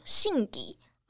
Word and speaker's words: 宝木: 裸祭呢，它有流传一段故事，就是据说在日本的奈良时代，他当时在举行新年祈福祭祀法会的时候啊，经过僧人们诵经加持过的神木信底，嗯，汉字写作宝木，简体字的宝贝的宝，然后再加上木头的木，念作信底。